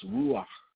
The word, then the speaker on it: ruach: The word spirit in Hebrew is pronounced ruach, which means to breathe or breath.